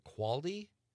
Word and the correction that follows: Claude: the cat? – Claude.